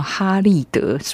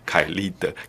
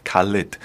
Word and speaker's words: Khalid: 没有，比如下面这个歌手叫哈利德，是我查出来的翻译，到底该怎么念？ – 凯利德 Khalid，他是一个美国的歌手，他也是我今天选的歌里头最年轻的一个，他今年才 19岁。